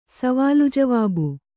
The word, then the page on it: ಸವಾಲುಜವಾಬು: savālu javābu